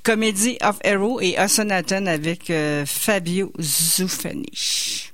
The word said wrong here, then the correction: Zuffanti: Fabio Zuffanti.